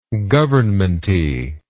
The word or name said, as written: governmenty